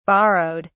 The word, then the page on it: borrowed: British English